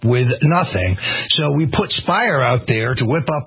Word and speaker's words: Speier: Spire rhymes with liar.